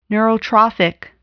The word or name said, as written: neurotrophic